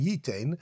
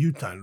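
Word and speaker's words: yutan (יֻתַּן): Now, the full pasuk is v'chi, well, the way we read it is actually not v'chi yiten. We read it in the Torah as v'chi yutan. It's spelt as v'chi yiten, but we read it v'chi yutan.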